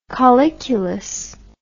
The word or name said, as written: colliculus